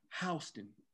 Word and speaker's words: Houston: But you want to know how they pronounce it, Ant? Houston, the way we pronounce it, the county, just the county they from, they call it Houston.